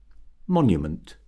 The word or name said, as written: monument